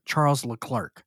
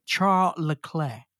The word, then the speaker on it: Charles Leclerc: But the American pronunciation is Charles Leclerc. – Yeah. – But the Brits say Charles Leclerc.